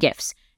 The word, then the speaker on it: GIF: JPEGs, PNGs, and GIFs. And yes, that's how I pronounce those.